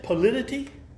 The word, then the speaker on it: polity: Polarity, the word politi. I guess that's how you pronounce it. I hope I'm right. Is the government. So FBCC, First Baptist Church Castleberry, has a congregational polity. Is that how you say it? Polity? Did I get it right? – Polity.